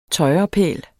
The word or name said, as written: tøjrepæl